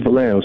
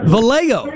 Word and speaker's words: Vallejo: The city's name is Vallejo, sir. – Vallejo.